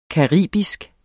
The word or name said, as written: caribisk